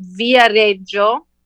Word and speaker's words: Viareggio: So in Tuscany, they have this little beach town called Viareggio. I'll spell it. V-I-A-R-E-G-G-I-O.